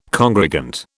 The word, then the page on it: congregant: British English